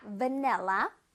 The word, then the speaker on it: vanilla: 아, 맞긴 하지만 제대로 발음하면 vanilla였지요. Vanilla, vanilla. One more time. Vanilla, vanilla. 자, V이기 때문에 윗니로 아랫 입술을 통통통 튀겨줘야 돼. V, V, V 할 수 있어요.